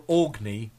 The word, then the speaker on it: Aurigny: Is it or, or, Orig, Origny? How do I pronounce that? or Organy. Spelled A U R I G N Y. Anyone listening, oh, anyone who's watching live, please uh, just make sure that I say that correctly.